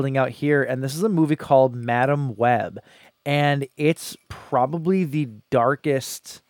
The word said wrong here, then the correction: Madame Web: I believe it's pronounced Madame Web.